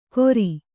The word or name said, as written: ಕೊರಿ